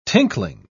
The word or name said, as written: tinkling